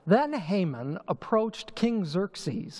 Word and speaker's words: Xerxes: It's an X. You pronounce it like it's a Z, Xerxes.